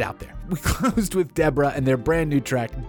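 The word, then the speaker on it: DE3RA: I believe they're pronounced Deborah. It's D-E-3-R-A. The three's got to be a B, right? It's like an uppercase B.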